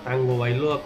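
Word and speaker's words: Tagovailoa: Sí, pero también está la, la situación en la que parecía que, que el Tua Taigo Bailoa, Tango Bailoa, como según él dice que se pronuncia al ser hawaiano. Mejor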